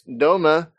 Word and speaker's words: Dohme: This morning, we are talking to Max Doma, developer.